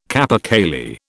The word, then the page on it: capercaillie: British English